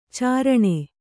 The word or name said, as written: ಚೀರಣೆ